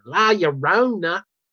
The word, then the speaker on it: La Llorona: Down, saying, La Llorona. – Yep. – Like her accent, like, does nail perfectly. – That's how I knew how to say it. I learned from Darla.